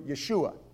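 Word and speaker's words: Yeshua (ישוע): His name was Jesus, which if you're new to Scripture, Jesus also was a very common name, otherwise known as Joshua, or pronounced in the Hebrew, Yeshua.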